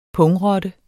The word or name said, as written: pungrotte